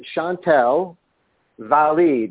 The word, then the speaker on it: Chantal Valli: Well, it's Valli. It is a French name, but I will take it the way you said it.